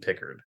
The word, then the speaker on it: Pickard: I have nothing else to add on the subject of Calvin Pickard. Because for the longest time, I called him Picard. And then they finally said his name properly.